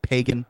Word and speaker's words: Pagan: So three islands, Pagan, which is spelled like pagan, but it's a good Catholic island, so we pronounce it Pagan.